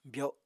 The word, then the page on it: byo7: apatani